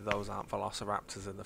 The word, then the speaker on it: Velociraptors: Velociraptors. – Syllable of stress off there. You were so close. – Velociraptors. Nah, it's Velociraptors.